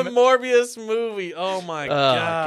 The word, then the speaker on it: Morbius: Was it going to be Morpheus? – You said the name wrong, but Morbius.